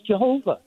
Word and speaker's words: Jehovah: Well, yeah, but the God of the Jews, the name YHVH, that's a V, not a W. And the name is Jehovah. That's how it's pronounced.